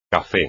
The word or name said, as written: café